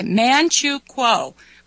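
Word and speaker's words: Manchukuo: And thanks to Bob in D.C., he mentioned a movie and then told me how to pronounce Manchu Kuo. Okay, Manchu Kuo. I don't know how I was saying, but I know it was wrong.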